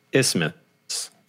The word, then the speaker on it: isthmus: Isthmus. – I don't know if that's right. – Okay. – Google says isthmus. Like it rhymes with Christmas.